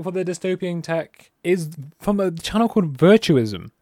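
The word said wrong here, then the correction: Veritasium: I might butcher the name, to be honest. Virtasium, sorry. I keep saying virtuous. It's Virtasium.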